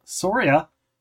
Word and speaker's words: Soria: Please welcome Destiny DL Soria. How are you doing? Did I say it right? I know there was, I've seen there's issues. It's like Gloria, but Soria, that's how it's pronounced.